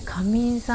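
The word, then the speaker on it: 夏眠: 夏に眠ると書いて、えー、仮眠さんって読むみ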